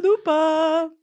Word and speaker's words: Quesalupa: Taco Bell is now bringing back the Quesalupa. Did I say it right? – Quesalupa.